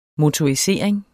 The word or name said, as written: motorisering